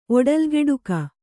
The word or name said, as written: ಒಡಲ್ಗೆಡುಕ